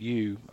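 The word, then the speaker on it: Ryu: Yeah, the So Yu, that's how you pronounce her. It's R-Y-U, but Yu, what a great athlete.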